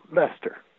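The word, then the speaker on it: Leicester: I called the international operator and get somebody in England, asked for Leicestershire. And she goes, what? She said, spell that. So I did, Leicestershire. And she said, oh, Lester.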